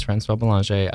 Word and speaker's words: Francois Belanger: So my name is Francois Belanger. I like how you pronounce it like I can speak French.